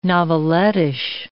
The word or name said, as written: novelettish